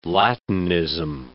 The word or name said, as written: Latinism